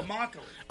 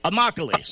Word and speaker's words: Immokalee: Now, I'm going to butcher this name, but I'm pretty sure it's spelled M-O-K-O-L-E. Hold on. But they beat Lake Placid 41-7. – Oh, Immokalee. – Immokalee. So, yeah, I totally butchered that one.